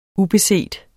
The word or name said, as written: ubeset